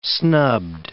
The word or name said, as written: snubbed